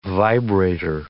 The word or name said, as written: vibrator